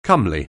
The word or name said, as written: comely